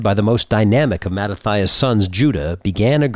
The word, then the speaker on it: Mattathias: Led by Mattathias, Mattathias in the Greek pronunciation, a family known as the Hasmoneans struck back.